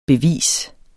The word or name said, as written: bevis